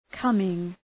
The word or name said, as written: coming